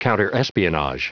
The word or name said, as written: counterespionage